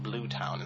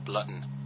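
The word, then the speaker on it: Bluton: No, it's actually not even spelled like the word town. It's B-L-U-T-O-N, but I like to call it Blue Town instead of Blutton.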